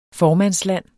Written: formandsland

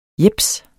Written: jeps

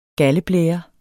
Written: galdeblære

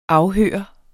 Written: afhøre

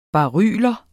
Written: baryler

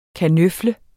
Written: kanøfle

